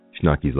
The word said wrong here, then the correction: Schoenke: last name. Schoenke? Am I right, Sean? Schoenke? All right, boys and girls. Thank you very much for joining us today. I'm gonna pronounce it the way that Sean's asking me to pronounce it.